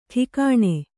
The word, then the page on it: ಠಿಕಾಣೆ: Kannada